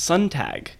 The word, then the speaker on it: Sontag: I realized last month when my parents were visiting from California that I've been pronouncing my own last name wrong. – No. – Say it. – How apparently, it's pronounced Sontag. – Sontag. – Okay. – And I just pronounce it how it's spelled.